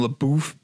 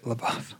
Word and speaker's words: LaBeouf: And also just like, you know, like you said, Shia LaBeouf had a great year and I've really I, I guess it is LaBeouf. I, I you know another podcaster calls him LaBoof, so I always say it like that now. Right, yeah, you're right, LaBeouf.